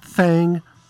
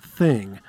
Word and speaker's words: thang: And we started off with the Homemade Jams Blues Band with Right Thang, Wrong Woman, and I am actually saying that correctly. The title includes the spelling T-H-A-N-G, and in my book, that's thang, not thing.